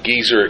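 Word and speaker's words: Gezer: He's built, by implication, the fortifications of Hazor, Megiddo, a place that might be pronounced geezer, except makes people my age a little nervous.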